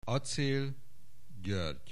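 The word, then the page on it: Aczél György: Hungarian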